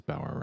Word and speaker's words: Bauer: Today we're talking about the Yield song, No Way, and my guest today is Ryan Bauer. Hello, Ryan. – Hey, how are you? – I am well, and it is Bauer, right? – It is.